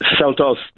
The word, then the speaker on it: Seltos: Well, the car you've been driving around on, is Kia, is it Seltos? Is that how you pronounce it? – Seltos, that's the way you pronounce it.